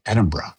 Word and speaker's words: Edinburgh: When you go to Edinburgh, which is how you pronounce it, Edinburgh. and I was told that after I said Edinburgh for many times, but they're like, it's Edinburgh, man.